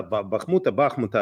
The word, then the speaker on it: Бахмута: Бахмута, оказывается, можно два ударения делать.